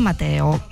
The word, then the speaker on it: Mateo: e la contea di San Mateo, Mateo con una T. Con una T. Eh sì, alla spagnola.